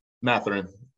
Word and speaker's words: Mathurin: What do you think of Mathurin? Am I saying that right? – Mathurin.